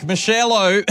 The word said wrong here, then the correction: Marshmello: That's not how you pronounce his name. It's Marshmello.